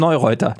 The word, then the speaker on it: Neureuther: Und Felix Neureuther, den spricht man wirklich so aus.